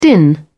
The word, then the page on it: din: British English